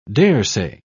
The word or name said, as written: daresay